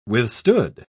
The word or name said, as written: withstood